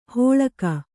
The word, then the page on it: ಹೋಳಕ: hōḷaka